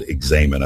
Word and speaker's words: examen: It's spelled examine, but it's called examen.